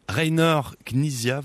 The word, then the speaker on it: Rainer Knizia: Rainer Knizia, vous me dites si je le prononce bien. – Oui.